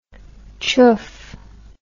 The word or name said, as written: choof